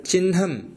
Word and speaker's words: चिह्नम्: उच्चारणार्थं वदामि यत् यत्र हकारस्य अनन्तरं नकारः अस्ति चेत् तत्र परम्परया वयं नकारस्य उच्चारणं प्रथमतया वदामः न तु अनन्तरम् उदाहरणार्थम् अत्र चिह्नम् इति अस्ति चिह्नमिति न इति अनुक्त्वा चिह्नमिति अनुक्त्वा चिह्नं तथैव ब्रह्म इति न ब्रह्म इति परम्परा अस्ति प्रथा अस्तु अग्रे